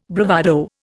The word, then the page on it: bravado: Indian